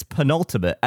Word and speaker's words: penultimate: You were saying pentultimate as though it's like the ultimate. – Yes. – But it's pronounced pentultimate, it's penultimate, and it means like one before.